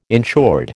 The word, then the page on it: insured: American English